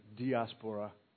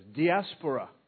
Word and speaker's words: diaspora: Diaspora. I mean, I didn't even say that right. It might be diaspora. So you say it both ways. Diaspora.